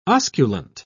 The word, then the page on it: osculant: British English